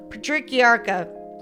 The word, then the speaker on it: Patriarca: Donati was linked to, and this, I had to look this up phonetically. If it didn't have a couple extra syllables, I'd just say the Patricia crime family, but let's call it the Patriarca crime family.